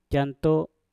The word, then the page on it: jan to: Mpur